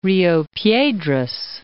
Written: Rio Piedras

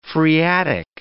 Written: phreatic

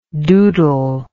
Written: doodlingly

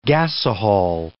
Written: gasohol

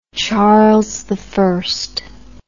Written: Charles I

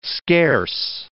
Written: scarce

